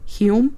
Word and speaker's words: Hulme: I think I'm saying this right. It's H-U-L-M-E, Hume.